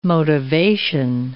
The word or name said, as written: motivation